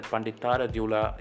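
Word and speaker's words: Panditaradhyula: I hope I said that Panditara Dula. Yeah, I said it right.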